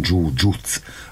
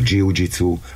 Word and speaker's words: jiu-jitsu: A jiu-jitsu, amit Japánban is hívják, japánosan jiu-jitsu a rendes kiejtés, de ugye halljuk kiejteni jiu-jitsunak, mint hogy mi is mondjuk, ez én inkább angolos, vagy a németes, meg a brazil területen a jiu-jitsu, ugyanarról van szó, csak ugye a kiejtés más, egy kicsit úgy, mint mikor mi azt mondjuk, hogy utca, vagy utca 100 évvel ezelőtt, vagy Miskolc, attól még az ugyanazt jelenti.